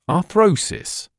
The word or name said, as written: arthrosis